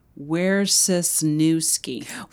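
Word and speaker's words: Wrzesniewski: Sure. It's actually Wierzerski.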